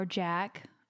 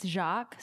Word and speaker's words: Jack: Hello, my name is Jacqueline, or Jack. Apparently some people think it's Jacques, so I just want to set the record straight that it's not. It's Jack, that rhymes with snack.